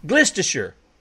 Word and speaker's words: Gloucestershire: Gloucestershire.